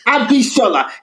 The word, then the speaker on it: Abishola: No weird throat sounds, no GB, you know, no, there's no it's just Abishola. It sounds exactly how it's spelled.